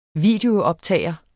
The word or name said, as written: VIDEOOPTAGER